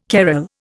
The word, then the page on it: carrel: Indian